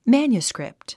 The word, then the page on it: manuscript: mǽnjuskrìpt; マァニュスクリプト